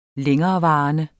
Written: længerevarende

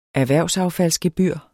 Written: erhvervsaffaldsgebyr

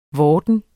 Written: VORDEN

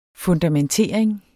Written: fundamentering

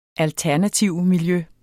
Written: alternativmiljø

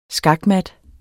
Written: skakmat

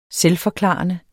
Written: selvforklarende

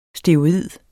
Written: steroid

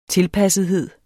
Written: tilpassethed

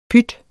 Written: pyt

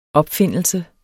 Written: opfindelse

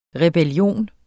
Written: REBELLION

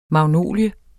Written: magnolie

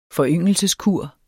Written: foryngelse

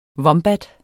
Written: vombat

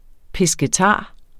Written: pescetar

